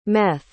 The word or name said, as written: meth